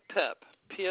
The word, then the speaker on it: Pipp: Mary Pip. P as in Peter, I.